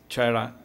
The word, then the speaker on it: Terra: For me it's hard to pronounce it as Terra, perché gli americani chiaramente la chiamano Terra. Per noi è una parola italiana, quindi Terra è più facile.